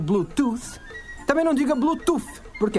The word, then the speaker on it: Bluetooth: Isso mesmo, Bluetooth. O final do som de tooth, ele é feito com a língua no meio dos dentes.